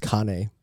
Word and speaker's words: Kane: It's probably pronounced Kane, but yeah.